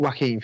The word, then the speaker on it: Joaquin: Like, is it Joaquin Phoenix how you pronounce it? – Yeah.